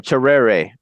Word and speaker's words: Tserere: Is it Chief Tessera? – Yeah. – How'd you say it? – Terrere, or Terrere. Yeah. It's spelled T-S-E-R-E-R-E.